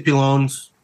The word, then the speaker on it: pilones: We got to actually see all the, what are they, pilones? Am I saying that right? – I say pilones.